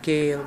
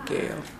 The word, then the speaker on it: Gayle: K-A-D-A-J-A-H. – L-A-I-D-L-E-Y. Lately. – Mm-hmm. And then your name? – Jacqueline. J-A-C-Q-U-E-L-I-N-E. Gale. G-A-Y-L-E. Gale.